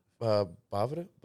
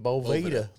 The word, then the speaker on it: Boveda: Today I learned it's Bavada, but. I keep saying Boveda, like Boveda.